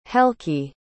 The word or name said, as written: helki